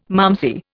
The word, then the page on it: malmsey: Indian